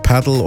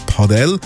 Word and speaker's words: Padel: Aber eben, ist ja aktuell mega im Hype, das Paddle, oder richtig ausgesprochen sagt man es eben Padel.